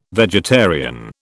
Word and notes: vegetarian: British English

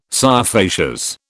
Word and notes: scire facias: British English

